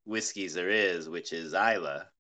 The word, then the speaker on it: Islay: It's spelled Islay, which looks like Islay, but it's Islay. And yeah, Islay. It's as peaty as it gets.